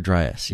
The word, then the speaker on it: Dryas: You write about this cooling event, the younger, is it Dryas? Is that how it's pronounced? – Younger Dryas, yeah.